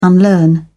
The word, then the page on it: unlearn: British English